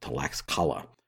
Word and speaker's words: Tlaxcala: I want to mention that because you may look at a map and see a place spelled T-L-A-X-C-A-L-A and think it will be pronounced something like Tlaxcala. But in reality, I'm saying Tlaxcala.